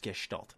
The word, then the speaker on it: Gestalt: remastering uh, NieR um, Gestalt, or I'm sorry, it should be pronounced Gestalt.